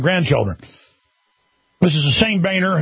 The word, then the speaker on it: Boehner: Well, that socialist, kleptocratic sellout to the New World Order, John Boehner, that's how he pronounced his name. We're not going to go under his mind control and call him Boehner anymore because he tells us to, has gone off on the right, as he calls it.